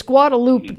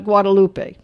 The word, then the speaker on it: Guadalupe: Well, it's Guadalupe, not Guadalupe. And Guadalupe Street, Guadalupe River.